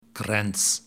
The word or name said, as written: Grants